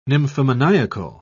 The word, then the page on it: nymphomaniacal: British English